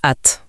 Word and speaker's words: at: Et en prime, en anglais, ça se prononce at, donc chez.